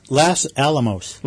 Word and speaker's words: Los Alamos: Los Alamos.